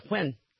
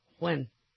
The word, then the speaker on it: Nguyen: That's not how you say it. It's Nguyen. That's how you say that last name. Nguyen.